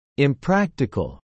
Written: impractical

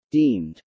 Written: deemed

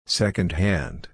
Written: secondhand

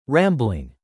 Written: rambling

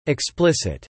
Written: explicit

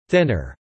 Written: thinner

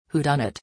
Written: whodunit

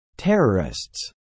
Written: terrorists

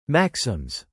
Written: maxims

